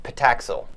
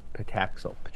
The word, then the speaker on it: Patacsil: How do you say it? – P-8 Pataxil. – Pataxil. – P-A-T-A-C-S-I-L.